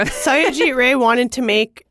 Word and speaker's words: Satyajit: Satyajit Ray? – Yeah.